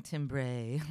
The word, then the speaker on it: timbre: It's spelled T A M, I'm sorry, it's spelled T I M B R E. It almost looks like timbre or something, timbre. but do not pronounce it like that. Not only will nobody understand what you're talking about, but also we specifically pronounce it timbre in American English to dis- dis- dis- specifically distinguish it and differentiate differentiate it from the other English word that is spelled timber, which is lumber, uh, and wood, T I M B E R, which is more much more phonetically uh, spelled.